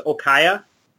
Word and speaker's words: Okja: I pronounce it Okja, but I think probably the proper pronunciation is, if you go by the little girl, she says Okaya.